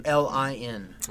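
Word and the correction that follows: Mjölnir: Okay. – Mjolnir.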